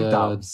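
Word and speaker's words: Doubs: How do we say his name? – Dobbs. – I think we're saying Dobbs. Yeah. – Dobbs.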